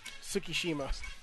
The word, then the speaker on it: Tsukishima: Tsukishima.